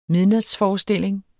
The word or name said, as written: midnatsforestilling